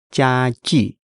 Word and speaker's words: 家具: Pay attention to the character 具, the pronunciation. It's the 语 sound. – You gotta pucker those lips. 家具.